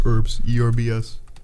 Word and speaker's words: herbs: You know is the H in herbs silent? American pronunciation of herbs is without the H. – The Brit. Oh, I'm pronouncing it the British way.